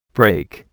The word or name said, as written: brake